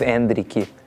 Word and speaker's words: Endrick: I like how you guys went with my Brazilian Portuguese pronunciation of Endrick.